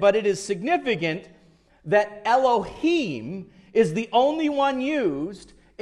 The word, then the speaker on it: Elohim: Understand that is pronounced Elohim, not El-El-Bean.